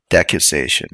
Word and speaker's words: decussation: Okay, let me double check the pronunciation of that word. It's decussation. It's like a branching to like an X-shaped.